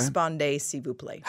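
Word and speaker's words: Répondez, s'il vous plaît: Respondez, s'il vous plaît.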